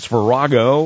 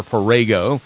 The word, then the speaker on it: farrago: It's Farrago or Farrago. Take your pick, the way you pronounce it. It is F-A-R-R-A-G-O, F-A-R-R-A-G-O, Farrago or Farrago, and it means a confused mixture or hodgepodge.